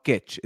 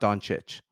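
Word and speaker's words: Dončić: It's Kitch. It's not Doncic.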